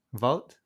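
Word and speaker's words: Wout: Wood. It's Wood, I think. – No, it's Vout. – Vout? – Yes. – Ah, okay. – It's Vout Weghorst. You say it like a V.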